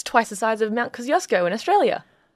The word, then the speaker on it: Kosciuszko: Kosciuszko.